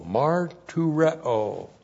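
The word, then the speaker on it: martureo (μαρτυρέω): Looks like that in the Greek, M-A-R-T-U-R-E, long O, martureo.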